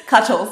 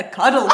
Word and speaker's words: cuttle: You did the Australian thing where I pronounce the T as a D. And you're like, a cuddle fish.